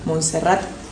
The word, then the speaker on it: Montserrat: Fuimos a Montserrat. ¿Lo dije bien? – Sí.